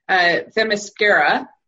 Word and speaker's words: Themyscira: And we get to the Themyscira.